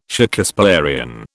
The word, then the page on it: Shakespearian: British English